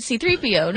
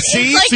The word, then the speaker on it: C-3PO: C-3PO. Like, I don't know. – Your emphasis is on the C. – C-3PO.